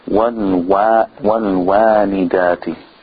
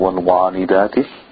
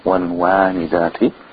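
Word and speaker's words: والوالدات: Wal-wa-nidati. Wal-wa-nidati. The waw is thin here. Not wal-wa-nidati. Wal-wa-nidati.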